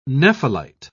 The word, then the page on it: nephelite: British English